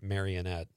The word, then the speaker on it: Marinette: Well, I live in Marinette. Everybody pronounces it Marionette.